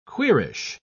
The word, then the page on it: queerish: British English